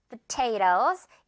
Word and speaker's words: potatoes: 먼저 이제 감자들을 mash, 으깨라라고 하면서 감자들 이 부분에 포인트 있습니다. Potatoes 이렇게 퍽 가볍게 갈 거예요.